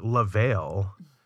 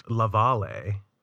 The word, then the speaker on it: La Vale: So there's a suburb of my hometown, and it's we call it La Vale, but it's really pronounced La Valle, the Valley by the way, yeah.